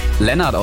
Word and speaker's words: Leonard: Obwohl im Englischen wird ja Leonard häufig Lennart ausgesprochen, was mich immer komplett verwirrt, ne? Zum Beispiel auch in der Serie Big Bang Theory. Hier dieser eine Typ, ne? Lennart. Wird er ausgesprochen? Wird aber Leonard geschrieben? Egal.